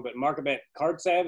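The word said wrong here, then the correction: Khadartsev: is that how you say it? – Markhabet Hadartsev.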